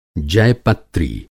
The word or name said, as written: जयपत्री